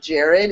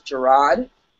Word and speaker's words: Gerard: Just like Gerard, it's not Jared, it's Gerard, which a lot of people don't really notice either.